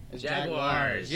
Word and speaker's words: Jaguars: Panthers at Jaggers. – Jaggers. – Let me change that over here too. – I'll go Jaggers. – How do you say it then? – Jaguars. – Jaguars. Let's go Jaguars.